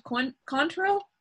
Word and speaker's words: Cointreau: And I never know how to say this correctly. 1/2 ounce of Cointreau. So it's like a Q. – Yeah. – But it's a C.